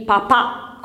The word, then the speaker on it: papà: Ok, ci siamo, ricordatevi il papà con l'accento sulla A il Papa scritto maiuscolo senza nessun accento e anche la pronuncia, sentite, il Papa è in Vaticano, il Papà è insieme alla mamma, va bene?